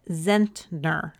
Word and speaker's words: Zentner: So again, his name's Alexi Zentner. I think I'm saying that right. It's Z E N T N E R.